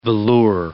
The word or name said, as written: velours